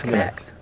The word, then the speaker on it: Kinect: There's been some other interesting kinetic. Kinect? Kinect? – I think it's kinetic. Like, Kinect? Oh, it is Kinect? – Yeah, it's Kinect. – Oh, okay. I've been saying it wrong.